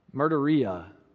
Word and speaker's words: martyria: In the Greek language, the word witness, when we talk about being a witness, this is how you say it in the Greek language martyria.